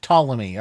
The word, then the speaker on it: Ptolemy: "p" ah, is silent. okay, like Ptolemy.